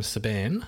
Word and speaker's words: Saban: So a guy called Haim Saban... I think Saban is how you say his last name.